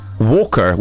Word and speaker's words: Walkr: It's spelled W-A-L-K-R, Walker, without the E. Interesting name there. Or Walkamon. This one is spelled W-O-K-A-M-O-N.